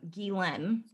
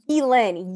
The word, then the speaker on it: Ghislaine: And I feel like it's like, you know how, I can't say her name, Giselle, or she's not Giselle, Gazelle, the one, Jeffrey Epstein's chick. – Oh, Ghislaine. – Ghislaine, yes. Okay,